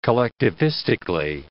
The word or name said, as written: collectivistically